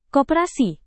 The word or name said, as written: koperasi